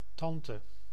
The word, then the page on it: tante: /tɑ̃tə/